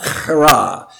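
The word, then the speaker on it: chara (χαρά): Chara. It looks like an X in the Greek writing, but it's a letter is chi. And it's chi alpha rho alpha. But the word is pronounced chara.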